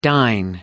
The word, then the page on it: dine out: American English